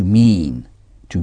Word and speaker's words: meant: Vouloir dire se dit « to mean ».« To mean » s'écrit « M-E-A-N ». C'est un verbe irrégulier. Mean, meant, meant. Ça s'écrit comme l'infinitif, avec un T à la fin. Meant, M-E-A-N-T. Le participe passé se dit lui aussi meant. Mean, meant, meant. Comment dit-on « qu'est-ce que vous voulez dire ?» What do you mean ? – What do you mean ?